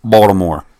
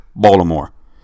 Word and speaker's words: Baltimore: That's how they say it down there. They roll. They roll it. So I'm going to say it one more time. Baltimore. Not Baltimore. They have no T in the word.